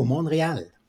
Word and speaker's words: Montreal: So please tell our listeners all about your exciting trip to Montreal. – Montreal, that's a good pronunciation.